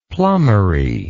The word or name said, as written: plumbery